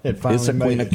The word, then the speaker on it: Issaquena: Issaquena County. – Issaquena County.